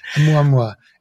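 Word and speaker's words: Oumuamua (ʻOumuamua): I think that's the correct way of saying it. Oumuamua. It's a Hawaiian word and it means scout.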